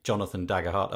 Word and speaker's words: Jonathan Daggerhart: I'm going to say Jonathan Daggerhart.